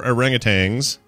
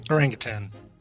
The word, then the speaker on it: orangutan: Well, yeah, but we also don't, there's no G at the end. So people who pronounce it orangutan, stop it.